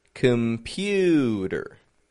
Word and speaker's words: computer: Listen for the syllable stress in this word here. Computer. Which is the stressed syllable? The middle, the pew, pew, computer. The C-O-M isn't getting a com, it's getting just a com, com. There's a schwa in there. Instead of a regular O, com, just a com. Pewder, and then a schwa R ending.